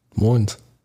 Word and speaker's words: Des Moines: But here's the weird thing about when you say Des Moines is you didn't pronounce the first S, yeah. – But you pronounced the second S.